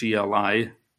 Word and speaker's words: Heli: Joseph's father, um, the best way to say it is Eli, it's spelled H E L I, but it's pronounced similar to Eli, was a devout Jewish man, and Joseph followed in his footsteps of his father, very devout.